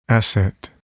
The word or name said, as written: asset